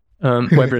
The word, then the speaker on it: Weber: Weber is like how I pronounce his name in my head. – I say that's what I often said. So I was like, shit, did I say it wrong earlier? So that's right.